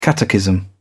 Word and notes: catechism: British English

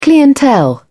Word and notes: clientele: British English

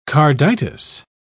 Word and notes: carditis: British English

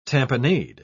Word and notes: tamponade: British English

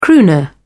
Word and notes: crooner: British English

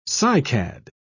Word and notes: cycad: British English

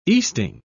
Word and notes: easting: British English

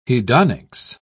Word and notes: hedonics: British English

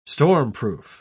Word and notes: stormproof: British English

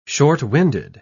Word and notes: short-winded: British English

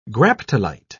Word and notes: graptolite: British English

